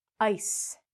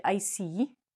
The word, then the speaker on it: ice: This word is ice. We don't say icy or easy. Okay. So again, we're going to say the I like an I, and the E is silent.